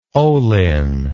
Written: olin

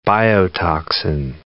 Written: biotoxin

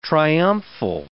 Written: triumphal

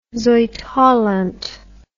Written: zuidholland

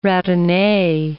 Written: ratine

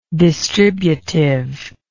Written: distributiveness

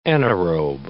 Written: anaerobe

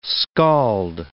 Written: scald

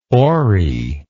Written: oary

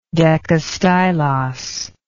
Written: decastylos

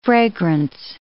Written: fragrance

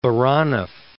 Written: Baranov

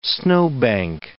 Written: snowbank

